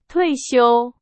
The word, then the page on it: 退休: Chinese